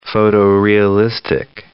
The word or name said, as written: photo-realistic